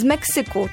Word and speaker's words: Meksyku: W Meksyku, o, jak nawet jeszcze źle akcentuję, bo z hiszpańskiego, gdzie mówi się Mexico. Z Meksyku, tak się akcentuje. prezentuje po polsku, moja droga Martynko.